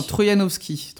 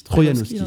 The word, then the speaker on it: Troïanowski: et donc, du coup, cet auteur, c'est, je suis désolé si jamais j'écorche son nom, c'est Johan Troyanowski. – Non, Troyanowski, Troyanowski.